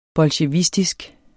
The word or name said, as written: bolsjevistisk